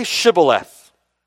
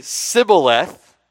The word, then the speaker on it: Shibboleth: They would say to him, okay, verse 6, then say Shibboleth. The Ephraimite would say Sibboleth, for he could not pronounce it right.